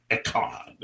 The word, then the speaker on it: Echad: It's E-C-H-A-D, Echad. That's how you say it in Hebrew, Echad, you know, with a guttural type sound, Echad.